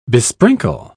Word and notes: besprinkle: British English

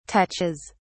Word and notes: touches: British English

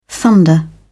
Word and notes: thunder: British English

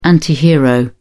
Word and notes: antihero: British English

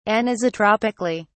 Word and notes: anisotropically: British English

